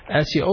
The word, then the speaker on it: Asya: Asya, also a good name, should be pronounced correct, because if it was pronounced wrong, it will change the meaning of this name specifically. Asya means pillar or support or helpful, and it is said that she was the wife of the Pharaoh. It's a good name to be giving to the girl. However, it's pronounced with a ayn, Asya, it changes the meaning to disobedient.